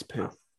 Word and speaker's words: Pugh: How do you say her last name? Pugh? Florence Pugh? – Pugh. It's Pugh. – Is it? – No, I think it's Pugh.